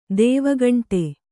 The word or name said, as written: ದೇವಗಂಟೆ